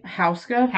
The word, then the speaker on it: Houska: I believe it's Hoska or Huska Castle. What would you say? – Houska? – Houska? We'll say Houska. Houska Castle.